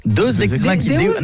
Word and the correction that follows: Deus ex machina: Deus ex machina. – Ah, parce que deux, j'aurais dit que c'était deux ex que t'appelles machine. – Non, ça se prononce Deus ex machina.